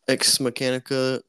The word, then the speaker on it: Ex Machina: I got Bullet Train, Inglourious Bastards Steelbook Edition, Us Steelbook Limited Edition, Ex Machina, and Blade Runner 2024. – What'd you say? Ex Mechanica? – Mechanica. How do you pronounce that? – Ex Machina. – Machina. Oh, okay. Machina.